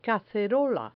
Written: cacerola

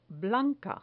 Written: blanca

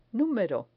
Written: numero

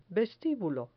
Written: vestibulo

